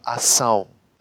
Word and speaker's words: Ação: A-C und dann ein weiteres C, das man hört. Ausgesprochen wird es aber Ação. Bedeutet, der stumme Konsonant C verschwindet jetzt einfach.